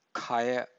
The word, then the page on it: khaya: Pali